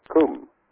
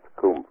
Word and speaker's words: cym: and they would like to have the extension CUM, which is spelled C-Y-M, but pronounced CUM for Cymru.